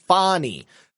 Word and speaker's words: Fani: I was also actually um, surprised to learn that her name is pronounced Fanny, but I'm always going to call her Fanny.